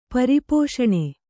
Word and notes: ಪರಿಪೋಷಣ: pari pōṣaṇa

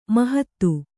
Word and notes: ಮಾಹತ್ತು: Kannada

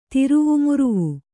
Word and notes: ತಿರುವುಮುರುವು: tiruvu muruvu

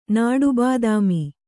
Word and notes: ನಾಟಿಬಾದಾಮಿ: nāṭI bādāmi

